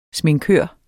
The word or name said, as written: sminkør